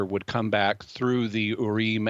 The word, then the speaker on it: Urim: In the Old Testament, the high priest had a couple of objects that were known as the Urim and Thummim, or as Americans say, the Urim and Thummim.